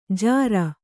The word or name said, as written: ಜಿರಿ